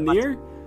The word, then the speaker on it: Nier: Are you talking about Nier? – Yeah.